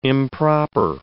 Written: improper

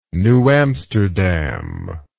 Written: new amsterdam

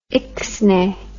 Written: ixnay

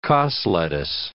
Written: cos lettuce

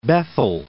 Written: Bethel Park

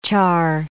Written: char

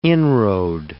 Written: inroad

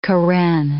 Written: Karen

